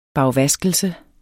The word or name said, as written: bagvaskelse